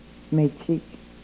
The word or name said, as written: mechik